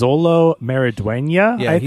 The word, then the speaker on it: Xolo Maridueña: His name is Xolo Maridueña, is how you pronounce it.